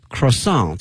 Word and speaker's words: croissant: 后面它是一个巧克力哈，chocolate croissant 这词呢，这个发音大家注意了，这个词啊应该是一个法语词，所以呢后面这个 t 呢是不发音的啊，把这个拼写给大家说一下，c r o i s s a n t 啊。那么这个词有的时候呢，在一些超市啊，在一些面包店会看的哈，croissant